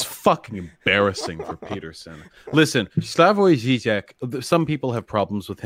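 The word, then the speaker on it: Slavoj Žižek: It's Slavoj Zizek.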